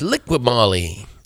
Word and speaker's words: Liqui Moly: Is it Liqui Moly Junior Cup? The Liqui Licky? – That's Liqui Moly. Liqui Moly, Scott.